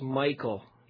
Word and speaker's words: Michal: Another opposition is, you know, actually her name's pronounced Michael, but I don't want to call her Michael.